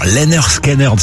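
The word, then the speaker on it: Lynyrd Skynyrd: Alors Lennar Skynyrd, ça se dit comme ça, oui.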